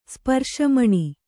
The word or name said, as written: ಸ್ಪರ್ಶಮಣಿ